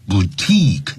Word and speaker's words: boutique: 好，任何一个字结尾是有 Q-U-E 的时候啊，特别记住呢，前面的 I 一定要念成 E，长元音，叫 unique boutique，那都是从法语过来的啊。